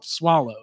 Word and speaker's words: Soileau: So finally I was like, hey, just to let you know, like, I don't care, but my last name is spelled Soileau, you know, or it's that's how you pronounce it.